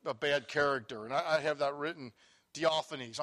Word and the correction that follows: Diotrephes: I'm sorry. Wasn't it Diophanes you talked about? Diotrephes. So I had that written in my Bible from a long time of study about certain men crept in unawares in the church.